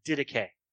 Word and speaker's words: Didache: That's how you pronounce it, Didache.